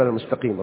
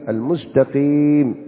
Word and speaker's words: المستقيم: لا زال البعض سهوا يقول المستقيم ربما بالطاء. فدرب نفسك أن تنطق بها نطقا صحيحا سليما. اهدنا الصراط المستقيم، المستقيم بالتاء.